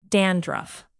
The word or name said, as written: dandruff